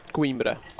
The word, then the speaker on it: Coimbra: Coimbra.